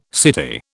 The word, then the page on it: city: British English